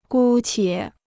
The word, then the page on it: 姑且: gū qiě